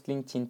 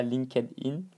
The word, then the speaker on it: LinkedIn: D'accord Bon, ça se prononce LinkedIn, pas LinkedIn, c'est de l'anglais.